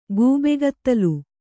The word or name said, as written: ಗೂಬೆಗತ್ತಲು